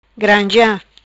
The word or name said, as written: Granzea